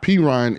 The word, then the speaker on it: Perine: and you know it's common knowledge that Samaje Perine, am I saying it correctly, the last name? – Perine is a much better pass protector than Joe Mixon.